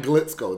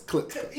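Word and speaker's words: Klitschko: It's not Glitzko. It's Klitzko.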